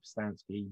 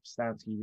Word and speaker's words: Pustansky: My name is David Pustanski. You can find me on Facebook, Instagram, and Twitter at David Pustanski, which is spelled P-U-S-T-A-N-S-K-Y.